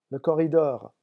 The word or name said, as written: le corridor